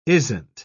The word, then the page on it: isn't: British English